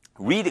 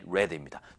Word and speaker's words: read: 그 다음에 read 같은 경우 읽다. 그러면 read 발음은 다릅니다. read, read입니다.